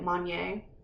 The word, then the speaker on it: Monnier: I'm pretty sure that's how you say it, Monnier, after being secluded in an attic for 25 years.